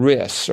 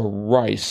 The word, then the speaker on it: Rhys: That was Gene Rice, or Rice, spelled R-H-Y-S.